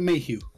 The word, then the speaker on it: Mayhew: Peter Mayhew.